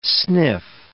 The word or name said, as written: sniff